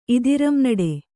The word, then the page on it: ಇದಿರಂನಡೆ: idiramnaḍe